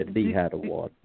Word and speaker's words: Nihad Awad: Nihad Awad. Am I saying that correctly? Probably not. – Yes, yes. – But yeah, Nihad Awad is how I said.